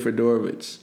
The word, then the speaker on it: Fedorowicz: CJ Fedorowicz. – You know, is that actually how you pronounce it? – Yeah.